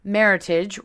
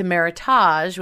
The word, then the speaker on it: Meritage: So the word is pronounced maritage, rhyming with heritage, as opposed to meritage, which seems to be the way many people pronounce it.